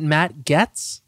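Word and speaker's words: Matt Gaetz: How do you pronounce it? Is it Matt Getz? – Oh, yeah. Right.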